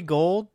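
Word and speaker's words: Gould: I mean it's it's not spelled like gold, but it's pronounced like like the like the the metal gold.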